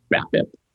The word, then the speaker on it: BABIP: BABIP.